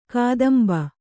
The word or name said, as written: ಕದಂಬ